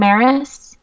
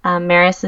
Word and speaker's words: Maris: The documentary is called I Am Maris. Maris is spelled M-A-R-I-S.